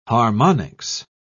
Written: harmonics

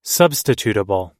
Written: substitutable

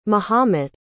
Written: mohamet

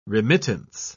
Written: remittance

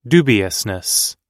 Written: dubiousness

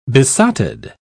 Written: besotted

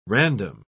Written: random

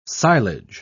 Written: silage

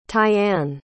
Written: tyanne

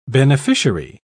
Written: beneficiary

